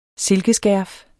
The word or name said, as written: silkeskærf